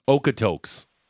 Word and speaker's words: Okotoks: Okotoks. – Spell it. – It is O-K-O-T-O-K-S.